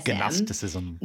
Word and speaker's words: Gnosticism: And if it's been a while since seminary or you haven't been to seminary yet, Gnosticism is spelled G-N-O-S-T-I-C-I-S-M. – Gnosticism. – Gnosticism. It's like G-G-Hero, like a hero, Yero.